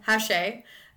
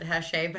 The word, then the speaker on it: Hache: How does she pronounce it? – Hache. I went back and listened to it and she does pronounce it Hache, but she also calls Adam Strong a 45-year-old shitbag dungeon dweller.